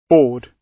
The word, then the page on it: board: British English